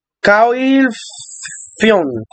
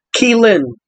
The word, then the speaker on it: Caoilfhionn: So it's spelled C-A-O-I-L-F-H-I-O-N-N. – So that's why we thought it was calcium or whatever the fuck. This is how it's actually pronounced. – Keelin.